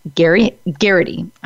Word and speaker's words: Geraghty: I would like to introduce all of you to the president and founder of Making Cancer Fun, Tara Geraghty.